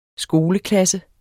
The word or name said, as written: skoleklasse